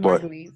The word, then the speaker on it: Margulies: Juliana Margulies. That's how I say her name. Is it said like that? Who knows? – Juliana Margulies. Yeah. – Margulies. Close there you go.